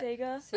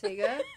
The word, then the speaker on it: Sega: Saga? How do you say that? – Sega. – Sega.